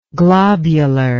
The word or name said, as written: globularly